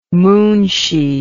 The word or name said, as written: moonshee